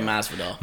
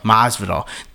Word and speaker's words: Masvidal: Diaz and Jorge Masvidal. – Masvidal, but that it was so disappointing that they ended that.